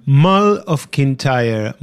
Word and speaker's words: Mull of Kintyre: Mull of Kintyre.